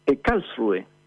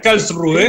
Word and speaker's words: Karlsruhe: Karlsruhe. – Ah, anduve cerca. Karlsruhe.